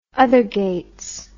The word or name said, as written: othergates